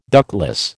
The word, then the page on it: ductless: American English